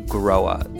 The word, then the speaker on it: Grohe: There is, and the company that is behind it is called Groha. It's spelt G-R-O-H-E, but pronounced kind of like you grow a flower.